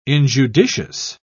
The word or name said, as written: injudicious